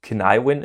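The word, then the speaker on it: Cynefin: Ähm, wo ich übrigens diese Woche gelernt habe, dass das gaelisch ausgesprochen wurde, irgendwie so Kinaiwin ist.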